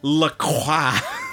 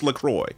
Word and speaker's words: LaCroix: But point is, Charles Lacroix. It's just too cool. And yes, his name is spelt like the water, which is pronounced LaCroix. – Okay. – And I will settle that right now. It's LaCroix.